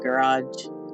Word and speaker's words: garage: spelled like garbage, but they call it garage.